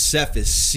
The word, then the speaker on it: Cephas: Yeah, it is Cephas.